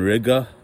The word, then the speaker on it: Riga: So, over the weekend I was at a nightclub called Valhalla here in Riga, which rhymes with nigga in Latvia.